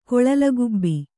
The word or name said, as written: ಕೊಳಲಗುಬ್ಬಿ